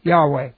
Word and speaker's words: Yahweh: So I pronounce it in the Hebrew, Yahweh.